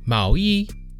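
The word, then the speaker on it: Mao Yi (毛衣): The first character, Mao, Mao, is said using the second tone, Mao. And by itself, it means hair, wool, or sometimes feathers. In pinyin, it is spelled as M A O. Meanwhile, E is said using the first tone. E. And by itself, this character means clothes. And in Pinyin, it is spelled as Y I.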